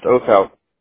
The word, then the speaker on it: Oathout: William, W-I-L-L-I-A-M. My middle initial is H. My last name is O-A-T-H-O-U-T. It's pronounced Othout.